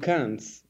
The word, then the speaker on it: Cannes: Oui, oui, and Cannes for the film festival on Cannes. – Yes, except for if you wanted to say it, you can't pronounce the S.